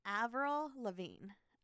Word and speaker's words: Avril Lavigne: Avril Lavigne. Oh, I said it right.